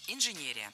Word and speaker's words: инженерия: Ударение в этом слове должно падать на третий слог – инженерия. В качестве шпаргалки предлагаю обратиться к однокоренному существительному инженер. Инженер – инженерия. При образовании слова на «ия» ударение не меняем.